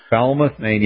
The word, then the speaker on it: Falmouth: Apparently in Maine, this is, how do you pronounce this? Falmouth, Maine? – Falmouth, Maine, yeah, in Hannaford Supermarket.